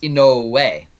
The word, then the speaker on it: Inoue: the late Hawaiian senator Inouye Ye, when actually the family name is pronounced Inoue.